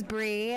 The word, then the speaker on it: Brie: Oh, well, my first name is Brie, and so it's so nice I-E. To nice to meet you, too. And I've had it pronounced so many different kinds of ways.